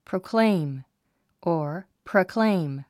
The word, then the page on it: proclaim: proukléim; プロォクレェイム